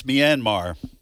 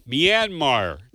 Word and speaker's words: Myanmar: I should have got – how do you pronounce that town? – That's Myanmar. – Myanmar. Thank you.